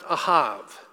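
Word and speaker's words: ahav (אהב): The word love in the Bible, the Hebrew word for love, is pronounced ahav.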